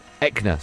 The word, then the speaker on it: Eckner: German broadcaster Konstantin Elkner, is that how you say his name? – Eckner, so I got the first bit right? – Yeah.